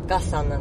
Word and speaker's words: 月山: でね、やっぱあの山形の中でも月山っていう山があるんですけど、月の山と書いて月山なんですけど、月山とかあとあれは何山脈なんだろうか、寒河江のあたりのね山がやっぱ 2000 メートル近いないんですけど、 2000 メートル近いあの山がいっぱいあって、そこはまだ雪かぶってるっていうのがすごいですよね。